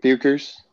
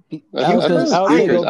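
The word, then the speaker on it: Bueckers: How do you say her last name? – Bukers. – Beckers? – Bukers. I haven't watched.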